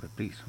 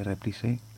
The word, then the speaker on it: Reprise: o Reprise mejor dicho, se escribe Reprise, los anglos de Inglaterra dirían Reprise, pero los americanos de Norteamérica Reprise.